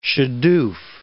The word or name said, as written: shaduf